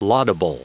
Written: laudable

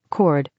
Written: cord

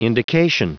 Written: indication

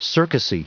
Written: circusy

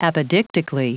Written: apodictically

